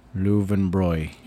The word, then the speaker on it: Löwenbräu: But it's called Löwenbräu. – And he will correct you every time. – It's Löwenbräu.